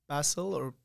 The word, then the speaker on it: Basil: Hey, guys, my name is Basil, depends on how you like to pronounce it.